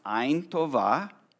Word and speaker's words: Ein Tova: Because the Jewish sages, even before Jesus' time, often spoke about a person who had, in Hebrew, it's pronounced Ein Tova, Hebrew for good eyes, versus a person who had Ein Ra'a, Hebrew for bad eyes.